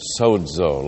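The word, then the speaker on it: sozo (σῴζω): The Greek verb is sozo, like S-O-Z-O.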